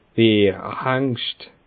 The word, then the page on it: Bierhengst: Bas Rhin; Schiltigheim